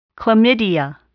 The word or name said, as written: Chlamydia